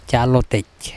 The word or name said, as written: calotEc